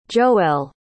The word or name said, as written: joell